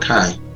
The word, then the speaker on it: kai: The word and is the Greek word kai. Kai. That's actually the pronunciation. Kai. Kai. But it's spelled K-A-I. That word and is the Greek word kai. K-A-I.